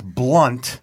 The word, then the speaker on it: Blount: Jeb and I have become pretty good friends, and I asked him, because he spells his last name B-L-O-U-N-T, and I was mispronouncing it Blount, and he said, no, it's Blunt.